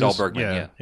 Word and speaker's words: Sandahl Bergman: Oh, we also remember, I think it was, how do you say her name? Sandahl Bergman is one of the muses. – Sandahl Bergman, yeah.